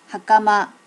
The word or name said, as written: hakama